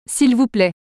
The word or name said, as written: svp